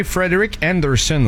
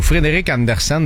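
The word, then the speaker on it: Frederik Andersen: Chez les Hurricanes de Caroline, s'entêter à utiliser Frederick Anderson ou Frederick Anderson, dépendamment de comment vous voulez le prononcer. – Frederick Anderson.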